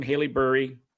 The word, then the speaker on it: Haileybury: You're originally from Haileybury, Ontario.